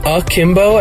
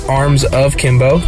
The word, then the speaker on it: Akimbo: Well, the two most important things are, one, it's pronounced Akimbo as in A-K-I-M-B-O, not Arms of Kimbo.